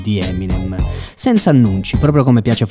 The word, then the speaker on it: Eminem: Nel 1996 ha pubblicato da solista il disco Infinite per la prima volta con lo pseudonimo Eminem, che è in pratica la pronuncia del suo precedente nome d'arte, appunto M&M.